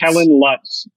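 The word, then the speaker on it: Kellan Lutz: Kellen Lutz.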